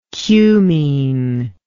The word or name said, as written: cumene